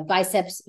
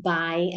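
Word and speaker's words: bíceps: It is not by and it's not try. That is never how an I is pronounced in Spanish. It's always E, bicep, tricep, quadricep.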